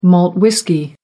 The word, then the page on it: malt whisky: American English